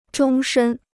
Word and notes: 终身: zhōng shēn

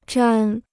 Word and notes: 沾: zhān

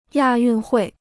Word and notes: 亚运会: yà yùn huì

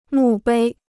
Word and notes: 墓碑: mù bēi

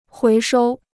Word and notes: 回收: huí shōu

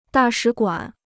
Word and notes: 大使馆: dà shǐ guǎn

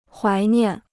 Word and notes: 怀念: huái niàn

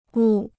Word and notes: 误: wù

